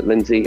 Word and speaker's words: Lindsay: If you want to have a look at Lindsay, LINDSAY, a bit of Scottish spelling there, by the way.